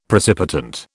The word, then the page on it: precipitant: British English